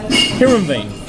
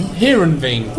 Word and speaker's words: Heerenveen: Heerenveen. – Heerenveen? – Heerenveen.